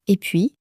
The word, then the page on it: et puis: French